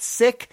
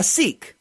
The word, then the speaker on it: Sikh: As a Sikh, that's S-I-K-H. Now, with respect to this word, I pronounce it Sikh. I've heard other folks pronounce it sick. I can't do it that way because of what the word sick means in English.